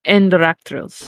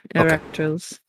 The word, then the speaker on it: Enraktrils: How do you say it? – Enraktrils. – Enraktrils? – Enraktrils. – Enraktrils. – Enraktrils. – Like arachnid. Enraktrils.